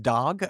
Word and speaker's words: Dag: We had a cat named, his name is spelled D-A-G, but it's pronounced dog. He played guitar.